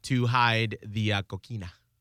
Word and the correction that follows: cocaína: I love that you always mispronounce the his coquina. – Cocaína. – Coquina. – Nope. – Cocaína. – Okay, that's enough. – All right, okay. Yes, Miguel is right.